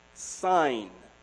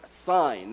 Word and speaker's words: Sin: Just in case you think that I do not know what I'm reading, it's pronounced Sin, not sin, but Sin. It was this from the Mount Sinai where it got its name. It's really pronounced S-I-G-N.